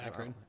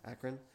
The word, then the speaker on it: Akron: Akron. – Akron.